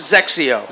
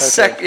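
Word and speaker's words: XXIO: Zexio. – Zexio. – Zexio. Yeah, kind of Sexio. Yeah. Sexio.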